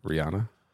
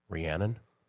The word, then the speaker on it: Rihanna: How do you pronounce her name? – Rihanna. – Rhiannon? – No. – No. – Rhiannon is the song from Fleetwood Mac. – Yeah. Correct. – Rihanna.